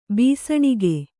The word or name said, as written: ಭಸಣಿಗೆ